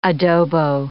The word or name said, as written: adobo